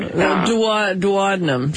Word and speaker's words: duodenum: I can't pronounce it. Duodenum? Is that right? Is very inflamed. – Duodenum.